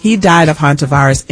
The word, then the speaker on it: Hantavirus: Hantavirus. – Hantavirus.